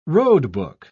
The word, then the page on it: roadbook: British English